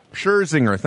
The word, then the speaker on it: Scherzinger: Scherzinger, thank you.